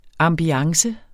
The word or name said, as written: ambiance